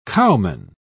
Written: cowman

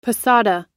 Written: posada

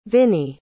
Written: vinie

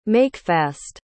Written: makefast

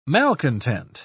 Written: malcontent

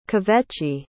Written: kvetchy

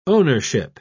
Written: ownership

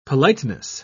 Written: politeness

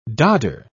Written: dodder

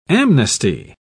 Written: amnesty

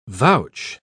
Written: vouch

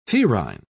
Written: ferine

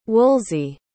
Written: woolsey

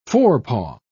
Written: forepaw

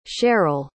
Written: cherryl

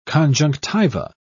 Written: conjunctiva

